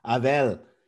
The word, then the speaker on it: Abel: I like the way you pronounce my name too. It feels like that's how my grandmother used to call me, Abel.